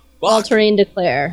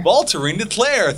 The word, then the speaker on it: Voltairine de Cleyre: Voltairine de Cleyre. – Voltairine de Cleyre.